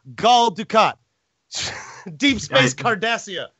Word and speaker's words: Dukat: Dukat.